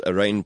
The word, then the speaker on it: Rayne: And that's R-A-Y-N-E. – R-A-Y-N-E, but pronounced rain down on me because I promised to make you wet.